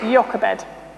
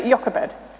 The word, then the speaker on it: Jochebed: Jochebed. I had to Google how to pronounce it because I wasn't 100% sure. But Jochebed.